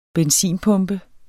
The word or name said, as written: benzinpumpe